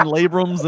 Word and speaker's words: labrum: Oh, God, but you pronounced it wrong. Everybody, labrum. – It's labrum.